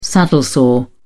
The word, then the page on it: saddle-sore: British English